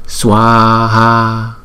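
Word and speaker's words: svāhā: Chúng ta phải đọc là swaha, swaha. Chữ swa là một nhịp, là một âm. Chữ swa là một âm và chữ ha là một âm. Tổng cộng là hai âm là swaha.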